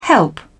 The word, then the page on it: help: British English